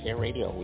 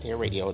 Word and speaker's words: HAI Radio: Yes, Hair Radio. We pronounce it Hair Radio. It's spelled with one R.